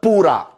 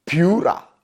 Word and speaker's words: Pura: Boss, ci că nu se pronunță pura, ci piura, bă măleș.